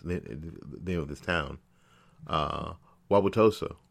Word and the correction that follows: Wauwatosa: wow, wow, Wauwatosa, after a police officer wasn't charged with a shooting that led to the death of 17 year old Alvin Cole.